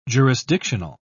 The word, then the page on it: jurisdictional: British English